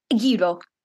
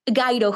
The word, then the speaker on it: gyro: When you order a sandwich in Greece, yes, you say gyro, gyro, gyro.